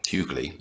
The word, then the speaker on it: Hughley: Complaining of paying expensive lawyers to get his daughters off charges, including one of DUI, Hughley, yes, that's how he insists it's pronounced, whines about mistreatment of blacks.